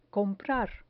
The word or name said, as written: comprar